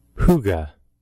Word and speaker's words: hygge: Huga.